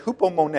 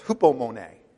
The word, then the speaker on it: hupomone: It's the Greek word for endurance here in James chapter 1, verse 3, and it's hupomone. I always think about the painter when I say that word, so I pronounce it right, Monet. But it's hupomone, all right?